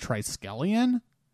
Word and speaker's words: Triskelion: So last week, when I mentioned the episodes for this week, I pronounced Triskelion, Triskelon. I know, it's terrible. And I just have to say, I know all of you out there were very upset, and you were all like, you don't know how to pronounce Triskelion?